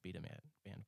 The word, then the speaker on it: Beta Band: It's pronounced Beta Band, by the way.